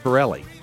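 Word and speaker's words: Pitrelli: It's P-I-T-R-E-L-L-I, but the T is silent. Pirelli.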